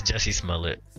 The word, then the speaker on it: Jussie Smollett: So, I just want to say it's Jussie Smollett. This is how you pronounce it,